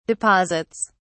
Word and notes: deposits: British English

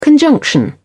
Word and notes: conjunction: British English

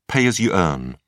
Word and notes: pay as you earn: British English

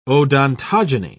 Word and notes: odontogeny: British English

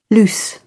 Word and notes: loose: British English

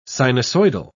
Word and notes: sinusoidal: British English

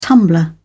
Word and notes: tumbler: British English

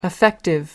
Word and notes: affective: American English

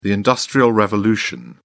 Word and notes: industrial revolution: British English

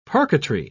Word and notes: parquetry: British English

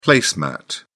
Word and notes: place mat: British English

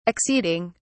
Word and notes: acceding: British English

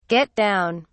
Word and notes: get down: British English

